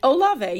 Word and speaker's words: Olave: How do you say that? Olave? – Olave, yeah. You got it right.